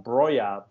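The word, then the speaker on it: Broja: And then we have Broha. This guy was playing, what was in Southampton last? – Actually pronounced Broya, but yes.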